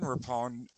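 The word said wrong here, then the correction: Rapinoe: in her last game to Rapino, it's pronounced Rapino.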